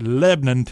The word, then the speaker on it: Lebanon: And you got to know how to say it. It's Lebanon, Tennessee. And so, yes, the Tennessee State Fair now moving a county to the east to Lebanon and Wilson County.